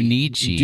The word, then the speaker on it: Junichi: Depending on which Pikachu you choose, a different track created by Pokemon music producer Jun, Jun, Juninchi. Wait, Ju, Juninchi? – Did I say that right? Junichi. – Junichi. Jun, There's Junichi. no extra N in there.